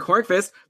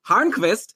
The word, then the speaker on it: Hornqvist: By the way, I mentioned Patrick Hornqvist. That's actually not how you pronounce his name. It's pronounced Hornqvist. And I learned this from patron John yesterday.